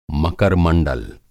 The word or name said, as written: मकर-मंडल